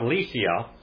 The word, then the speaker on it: Lycia: The upsilon, as they call it, is actually a U to them, but it is not. It is a Y sound. And here we have Lycia and Myria.